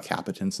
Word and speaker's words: capacitance: It wasn't failed on me, but it's like now that I know more about cables, I know about capacitance and stuff like that. Did I say that right? Felt wrong. – No. Geez, you got me saying it wrong. – You say it the right way.